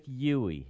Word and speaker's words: Yui: Spelled Y-U-I, but pronounced Yui. – Y-U-I.